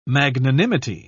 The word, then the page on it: magnanimity: British English